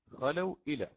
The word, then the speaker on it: خلوا: إذا خلوا إلى شياطينهم، خلوا هكذا، لحظة فقط حتى أكتب الواو عليها السكون، تمام، أصلها هكذا، خلوا إلى، الواو هي ساكنة، خلوا إلى. الألف التي بعدها هذه لا تنطق، تمام، لا يتلفظ بها، ترسم نعم ولكن لا يعتد بها.